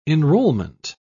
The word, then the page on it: enrollment: British English